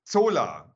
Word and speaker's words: Zola: Solar oder Zola? – Solar mit Z. – Zola, also gut, bei Zola.